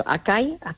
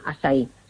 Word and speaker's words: açaí: Si lo queréis buscar escrito, que se escribe A con la cedilla, como si fuera esta portuguesa, y se llama eso Acai orgánico, y se pronuncia açaí.